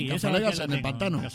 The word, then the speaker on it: Cazalegas: ¿En Casallegas? Serán Cazalegas. – Sí, pero yo lo pronuncio así. – Claro. – No, es que para entender hay que saber inglés. – Es que es la Es un pijo. – Casaliguis. Cazalegas, coño.